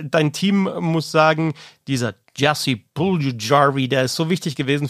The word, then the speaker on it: Jesse Puljujärvi: Jesse Puljujärvi, ich helfe nochmal mit der Aussprache, Jesse Puljujärvi, der war jetzt für die Florida Panthers in dieser Saison nicht so wichtig, aber ist es eben für die Charlotte Checkers.